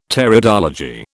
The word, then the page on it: Pteridology: British English